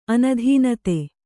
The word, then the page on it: ಅನುದಾನಿತ: anudānita